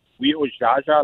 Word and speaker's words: Weojaja: So it's Weojaja, like Jaja Gabor.